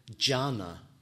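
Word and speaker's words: jhāna: And Chan is the Chinese way of pronouncing the Indian word jhana, jhana or dhyana, which means concentration or absorption.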